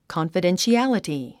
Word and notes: confidentiality: kὰnfədenʃiǽləti; カンファデンシアリティ

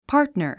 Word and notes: partner: pɑ’ː r tnə r; パートナァ

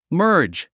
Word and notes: merge: mə́ː r dʒ; マージ

